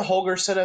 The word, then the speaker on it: Holgorsen: Is it Dana Holgorsen as the head coach, or am I mispronouncing that? – I believe that's how you say his name.